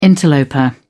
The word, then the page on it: interloper: British English